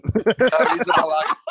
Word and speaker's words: Arrizabalaga: But to be able to slot home like that, and again, Arrizabalaga, I believe is the way you pronounce that name. – I'm glad you did it and I didn't. Arrizabalaga.